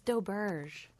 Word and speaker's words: Doberge: It's Doberge. – Doberge. – Or maybe Doberge, but it's not a, there's no E at the end.